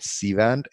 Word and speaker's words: Cvent: I work for a company called Cvent. It's spelled as C-V-E-N-T.